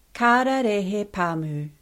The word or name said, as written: Kararehe